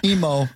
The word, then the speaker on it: Emo: Emu, how do you say it? Emo? Emu? – Emo. But Emo.